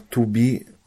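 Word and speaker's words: tubi (тобі): W słowach tubi, subi, czyli pisane tobi, sobi, Też jest to zauważalne, dlatego że później mam akcentowane i.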